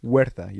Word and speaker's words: Huerta: The actor, Tenoch Huerta, Huerta, you don't pronounce the H, Hector.